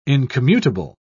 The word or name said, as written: incommutable